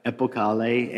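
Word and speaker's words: Epokale: It is a, it's, how do you pronounce it? – Epocale.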